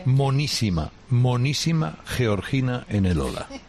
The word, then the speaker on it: Georgina: Es que lo ha pronunciado como debe, Georgina. – Georgina, porque ella es de Jaca.